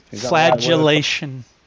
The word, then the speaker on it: flagellation: But I didn't hear any L in the word you said. Flagellation.